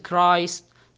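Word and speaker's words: Christ: ومثل ما تفضلنا حرف السي والاتش ينطق حرف الكاف في حالات شاذة، مثل كلمة كيمستري معناها كيمياء، وعندنا كلمة كريستيان معناها اللي هو نصراني أو مسيحي، أو كلمة كرايست نفس كلمة كريستيان، كرايست معناها طبعا المسيح.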